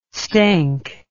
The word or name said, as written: stank